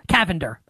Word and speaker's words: Cavender: How do I pronounce this, guys? I should have asked this off air. Cavender? Like the attractive twins from women's college sports. Cavender? Tyler, is that right? He said the... I don't remember what the pronunciation was. – Yes. – Cavender. Okay. Featuring...